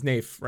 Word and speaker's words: Naif: Yeah, so like Naif and Set, which is Naif, right? Am I getting that right this time now that I know how to say it? It's Naif, N-A, like Waif, but with an N.